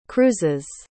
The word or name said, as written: cruises